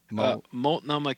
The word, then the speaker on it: Multnomah: A Spanish regional technology coordinator at the, how do you say the name of your library? – Multnomah County Library. – Multnomah County Library.